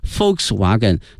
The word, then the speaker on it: Volkswagen: 首先看一下大众、啊、 VW，它的全称呢是 Volkswagen，因为在德语里面这个 Volks、啊、注意一下这个 V、啊、它的发音呢是 F 音、啊、 Volkswagen 它表示人民的意思， People， Wagon 呢是车， Volkswagen 这个词德语呢翻译成英语呢是 People's Car，翻译成中文呢是大众。